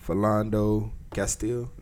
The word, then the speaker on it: Philando Castile: Alton Sterling in Baton Rouge and Philando Castile. Am I saying it right? – Sound like that's about right.